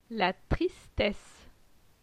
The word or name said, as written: La tristesse